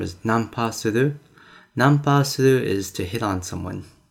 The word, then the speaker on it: nanpa suru: How do you say that? – Nanpa suru. – Nanpa suru. – Suru. S-U-R-U.